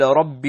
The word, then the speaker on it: رب: Technically, it's like قال يا ربي, يا ربي, يا ربي, but we do not pronounce the ya, nor the ya at the end.